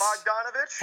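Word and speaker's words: Bogdanović: Bogdanovich? – Heck yeah.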